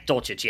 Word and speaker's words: Dulcich: Yeah, Dulcich, yeah.